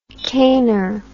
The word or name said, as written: cainer